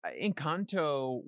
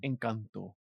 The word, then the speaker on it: Encanto: So Encanto was a... – I'm sorry. I think you said it wrong. – Encanto. – Thank you so much. Encanto.